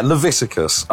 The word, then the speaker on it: Leviticus: Leviticus? Is that right? – Yeah, Leviticus, which is, if you ever heard a Bible thumper try and like torture the gay community, they usually invoke the book of Leviticus.